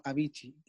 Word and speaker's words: Avicii: Sí, lo dije bien, no es la marca, sino Avicii, el músico.